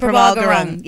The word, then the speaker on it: Prabal Gurung: Prabal Gurung. That's how I'm going to say it. Prabal Gurung. – Yeah. – You got to say it like so fast.